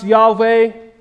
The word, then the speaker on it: Yahweh: God has revealed that his personal name, I believe, is pronounced Yahweh.